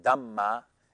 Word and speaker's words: Dhamma: The Dharma is pronounced Dhamma in Pali, like the Dhammapada.